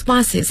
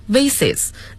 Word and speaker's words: vase: They are glass vases and so on, 都是玻璃花瓶还有诸如此类的东西。那么刚才的读音是 vases, 那么还有一个发音是 vase, 一个是 vase, 一个是 vase, 英音 vase, 美音 vase。